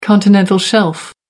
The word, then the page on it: continental shelf: American English